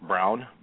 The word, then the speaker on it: Broun: Paul, I believe it's pronounced Brown, B-R-O-U-N, he voted for Alan West.